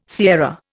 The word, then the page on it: sierra: Indian